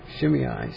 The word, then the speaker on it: Shimei: Finally, Jonathan, the son of Shimei, David's brother, because it's written like that, two separate vowels at the end, Shimei seems like the way to pronounce it, and David's brother struck him down, the son of David's brother, Shimei.